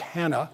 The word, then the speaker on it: Anna: Her name would have been pronounced Hannah in the Hebrew world. Anna is the way the Greeks would have said it.